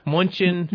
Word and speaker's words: Mnuchin: is it Mnuchin? – Mnuchin sounds. – Mnuchin? – Yeah. – Is that how you say it? – Well, sure. I know either Mnuchin, I guess. – Yeah. – Mnuchin. – It sounds more plausible. – Okay. – It's about 50-50 people pronounce it out there.